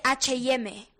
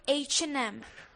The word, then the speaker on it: H&M: No se dice H&M, se dice H&M. – Bueno, perdona. – H&M. – H&M. Pues perdona que corrija a Paris Daniel, pero resulta que H&M es sueca. Así que o dices H&M en sueco, y creo que ni ella ni nosotros lo sabemos, o lo sueltas en español, porque en España hay H y hay M.